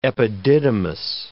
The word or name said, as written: epididymis